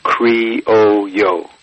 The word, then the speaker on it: Criollo: This phonetically is Criollo. – Yeah. – Criollo. – Yeah. Okay. – And so I'll have to look up the, I think it's C-R-I-O-L-L-O, Criollo.